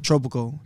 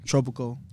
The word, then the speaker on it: Tropico: I mean, I really pronounce it Tropico. Tropico.